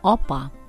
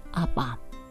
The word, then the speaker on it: апа: Как уже говорилось ранее, звук «о» в татарском языке отличается огубленностью, поэтому говорим «опа», ни в коем случае не «апа».